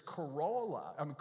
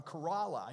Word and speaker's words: Corolla: If you're going to the beach and you want to go where the horses are, I've mispronounced this for years. It's Corolla. Corolla. I even do it wrong now. Corolla, not Corolla. They'll know you're from somewhere else.